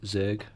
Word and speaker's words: Zurg: It's pronounced Zurg. – Zurg, sorry, yeah, I got it wrong.